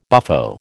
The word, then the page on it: buffo: American English